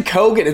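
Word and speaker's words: Keoghan: Barry Keown. Keown. – Keegan. – Ke- it's it's Keegan? actually Kogan.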